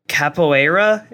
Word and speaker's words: capoeira: It's um uh capoeira.